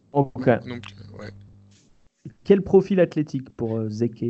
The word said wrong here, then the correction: Zeke: euh, Zeke du coup Alors c'est un, poste intérieur, il joue 4 euh, pour l'instant à Arizona, il était...